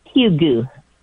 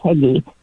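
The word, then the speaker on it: hygge: But he used to call it Hugu is how you say it. – Okay. – Not Heggy, but it's Hugu. – Oh, so basically H-U-E-G-U-H.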